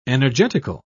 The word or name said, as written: energetical